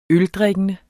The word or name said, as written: øldrikkende